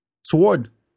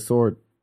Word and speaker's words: sword: Does he pronounce the W in sword? – Sword. – Do you? – No, I don't. I said sword.